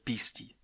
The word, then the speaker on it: Beastie: The original mascot is called Beastie, a distorted pronunciation of BSD.